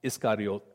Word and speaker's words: Iscariot: Then one of the twelve, the one called Judas Iscariot, it's actually pronounced Iscariot. And it just means that it's a person from the region of Kerioth, Judas from Kerioth.